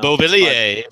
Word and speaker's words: Beauvillier: But so to get Barzal or Beauvillier, if that's how you say it, I don't know. – Beauvillier, yes, the French.